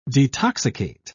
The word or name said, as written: detoxicate